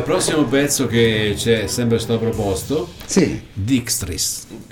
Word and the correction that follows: District: District. – Vedi Roberto District. come lo dice bene. Par, farlo pronunciare a Harry che Pro- mm, l'ha pronunciato bene.